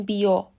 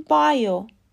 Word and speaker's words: bio: اما حواستون باشه اگر دارید به زبان انگلیسی حرف میزنین نگیم بیو باید بگیم بایو